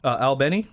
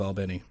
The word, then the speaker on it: Albany: We have a Miracle Toyota and Miracle Kia of Albany. – Is that how they really pronounce that? It's not Albany? – Oh, no, no. If you're in Georgia and you call it Albany, you're obviously not from Georgia.